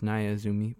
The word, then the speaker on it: Naya Izumi: We just heard Naya Izumi, which I mispronounced. It's Naya Izumi playing the song Soft Spoken Woman, and then Signals playing the song Lungs Apart.